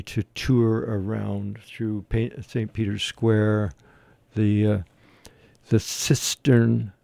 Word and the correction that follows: Sistine: Did I pronounce that correctly? And it was pretty Sistine. That's what it is, Sistine Chapel.